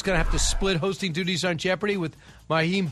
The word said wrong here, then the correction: Mayim: Bialik. Did I say that right? – I believe Mayim. – Yeah, Mayim Bialik.